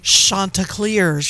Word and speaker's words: Chanticleers: How do you say their mascot? Help us out here. When I say Coastal Carolina, you say the Chanticleers. – Chanticleers. – Not the Chanticleers. Chanticleers or Chandeliers. – My apologies to Myrtle Beach, South Carolina, and the Coastal Carolina Nation for my mispronunciation earlier when I was promoting.